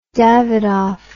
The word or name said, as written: davidoff